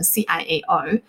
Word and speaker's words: ciao: 那如果改成 C I A O，啊，至少他们会比较容易发音一些。